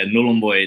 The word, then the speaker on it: Nullarbor: Yeah, it's a bit of a tricky one, but yeah, Nullarbor, it's in Northern Territory in Far East Arnhem Land.